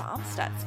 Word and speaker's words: Amstutz: And you can find me on all social media platforms at Sandra Amstutz. My last name is spelled A-M-S-T-U-T-Z.